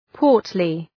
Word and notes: portly: ‘pɔ:rtlı

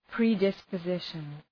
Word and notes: predisposition: prı,dıspə’zıʃən

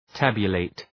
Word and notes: tabulate: ‘tæbjə,leıt

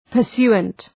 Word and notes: pursuant: pər’su:ənt

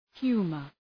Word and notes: humor: ‘hju:mər

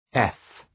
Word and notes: F: ef

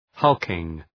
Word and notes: hulking: ‘hʌlkıŋ

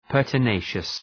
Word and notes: pertinacious: ,pɜ:rtə’neıʃəs

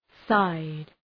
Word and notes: side: saıd

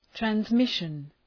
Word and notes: transmission: trænz’mıʃən; træns’mıʃən

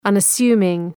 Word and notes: unassuming: ,ʌnə’su:mıŋ

